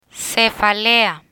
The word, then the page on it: cefalea: spanish